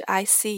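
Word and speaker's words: ICY: I C Y 呢，其实是曹芳英文名字的拼写，它的发音应该是 I C，也就是我知道的意思。加上 Y 的话，只是为了好发音。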